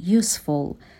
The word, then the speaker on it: useful: This is particularly useful. تلفظ useful رو هم دقت بکنین. صدای سه داره.